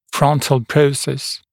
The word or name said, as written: frontal process